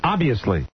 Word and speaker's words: obviously: I think the V slows you down. – To pronounce the V. – Yes. Obviously.